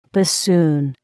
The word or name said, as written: bassoon